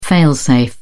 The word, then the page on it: fail-safe: British English